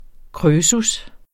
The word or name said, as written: krøsus